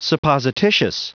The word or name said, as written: supposititious